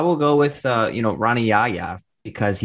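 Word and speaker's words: Rani Yahya: They played the audio file for how we have always called him, Honey Yaya. He calls himself Ronnie Yaya. He has Americanized his name.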